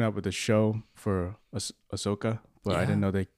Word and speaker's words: Ahsoka: I'm not interested in anything except for Osaka. Nope. How do you pronounce her name? – Ahsoka with Rosario Dawson.